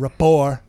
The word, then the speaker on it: rapport: You're not going to have the kind of rapport. Okay, it's a silent T. Not a lot of people know that. You got to have a rapport, okay?